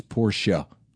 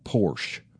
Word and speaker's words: Porsche: You know, it is a two-syllable word, right? It's Porsche. It's not Porsche. If you hear somebody say Porsche, then you know they don't know what they're talking about.